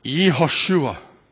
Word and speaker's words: Yehoshua: You know, the names of people in the Old Testament, Joshua, as an example, we pronounce it Joshua. But Joshua is a wonderful name. We have a son named Joshua, as you probably know. But it's Yehoshua in Hebrew.